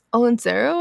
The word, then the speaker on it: Olentzero: So it's Olentzero, I believe is how you say it.